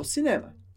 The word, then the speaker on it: cinéma: Pronúncia da palavra cinema com a sílaba mais forte, a última. Cinema.